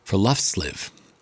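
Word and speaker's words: friluftsliv: So there's this Norwegian concept known as forluftsliv.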